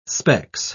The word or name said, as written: specs